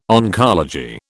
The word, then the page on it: oncology: British English